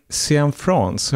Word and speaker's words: Cianfrance: av Derek Cianfrance. Har jag uttalat det rätt nu, Seger? – Ja, jag tror det.